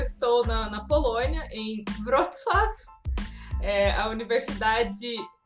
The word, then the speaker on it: Wrocław: É, em português é chamado de Breslávia, é um nome que vem tem origem alemã, mas a, em polonês é Vro, é, se escreve Wrocław com W e se lê Wrocław.